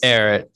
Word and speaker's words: Erret: It's Eric. E-R-R-E-T. Eric. Okay, yeah. It's like Eric, like, but with T.